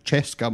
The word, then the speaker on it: CSKA: Player number five played for Zalgiris Vilnius, CSKA Moscow, which I believe is pronounced Cheska Moscow, but I knew if I said that it would just confuse you.